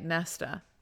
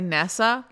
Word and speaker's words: Nesta: And I know that that's how they say it, Nesta. Do I say Nessa like there's no T? Yeah, I do. Mm. Why is that, you might ask? I couldn't say. – There's a T.